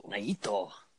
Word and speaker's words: Naito: Naito. – And I'm not doing that to make fun of Japanese people. I'm doing that because that's how you pronounce his freaking name.